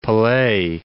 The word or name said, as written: Pelee, Mount